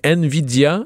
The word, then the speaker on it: Nvidia: Euh, c'est Nvidia.